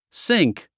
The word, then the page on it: sink: síŋk; スィンク